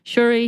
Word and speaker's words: Shuri: So, Iron Man, Captain America, Hulk, Thor, Black Widow, Black Panther, Ant-Man, Scarlet Witch, Shuri.